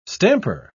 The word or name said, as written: stamper